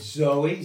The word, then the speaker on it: Zoe: Okay, it's Zoe, Z-O-E, long E.